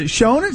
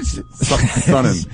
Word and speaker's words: Sonnen: I don't know who Sonnen was, but Sonnen. What is it? Shonen? – Sonnen.